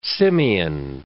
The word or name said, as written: Simeon